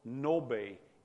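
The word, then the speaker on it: Ngäbe: Handy little tool to learn some more about these people called Nobe, N-G-A-B-E. That doesn't sound like Nobe. The G is silent. The A has two dots over it.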